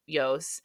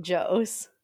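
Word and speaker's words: Jos: And the rumors on the circuit right now are that Max's camp, so Helmet, Yos, were responsible for the leaks. – Proud of you for pronouncing it Yos and not Joes.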